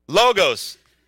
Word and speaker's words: logos: You got to have a, a I'm going to say it wrong again, a log, say it again, logos Okay, logos.